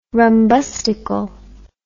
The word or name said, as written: rumbustical